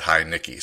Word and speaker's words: Heinicke: I tweeted out a question, how do we pronounce his name? And most everybody said Heinicke. So we're going to – I'm going to try to go with Heinicke because it looks like Heineken to me.